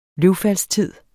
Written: løvfaldstid

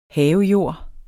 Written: havejord

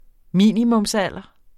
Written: minimumsalder

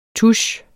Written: tusch

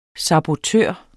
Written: sabotør

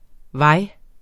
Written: vej

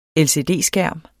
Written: LCD-

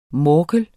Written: morkel

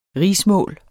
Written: rigsmål

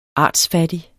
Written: artsfattig

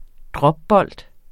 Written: dropbold

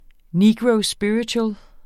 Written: NEGRO

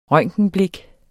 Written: røntgenblik

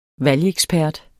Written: valgekspert

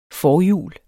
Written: forhjul

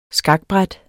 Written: skakbræt

